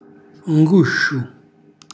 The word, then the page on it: ngusciu: calabrese